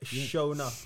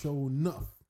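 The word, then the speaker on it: Sho'nuff: Show, like, I spell it SHO apostrophe NUF. Shonuff. – Shonuff.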